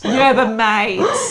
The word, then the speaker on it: Yerba mate: Yeah, it's not phonically. – It's not mate. – Mate. – Yerba mate.